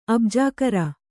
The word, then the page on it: ಅಬ್ಜಾಕರ: abjākara